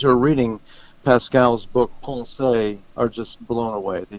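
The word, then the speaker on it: Pensées: It's Pascal's book, which is spelled in English like it would be pronounced Pensees, but actually the French, I'm told by those who know, is pensée.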